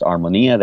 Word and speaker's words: Armonia: It looks like Armonia, but it's pronounced Armonia, the accent's on the I.